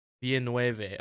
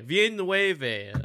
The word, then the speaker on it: Villeneuve: I already forgot how to pronounce. – Villeneuve. – Okay, Villeneuve. All right, I gotta write that in my brain.